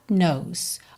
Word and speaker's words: knows: God knows, atenção, para não dizer God, e atenção que esse K de knows é mudo. Então, a gente tem God knows.